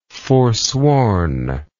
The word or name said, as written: forswornness